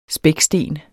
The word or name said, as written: spæksten